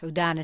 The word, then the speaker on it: Houdin: And of course, Houdin is spelled H-O-U-D-I-N, the H is silent.